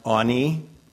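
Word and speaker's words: ani (עני): It's spelled A-I-N-Y in English. It's pronounced ani, ani.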